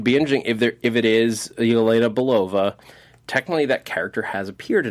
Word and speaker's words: Yelena Belova: It's Yelena Belova.